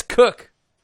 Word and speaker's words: Koch: Wow, he said it's Cook. How would you? Pr- why would you pronounce K O C H as Cook?